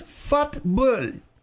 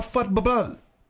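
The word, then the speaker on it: fútbol: You said F-U-T-B-O-L. – Oh, yeah, the proper pronunciation, yes, is fut-bull or fut-bubble.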